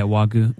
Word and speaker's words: Wagyu: It was like Wagyu. Is that how you say it? – Yeah, Wagyu.